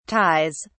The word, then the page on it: ties: British English